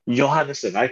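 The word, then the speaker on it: Johannessen: One rider who, actually there's 3 riders that I'm really excited about on your team, on the men's team, and is the two Johansson twins. – Yes. – Is that how you say it? – Yeah. – Last name? Now, what's his Johannesson. – Johannesson. Okay.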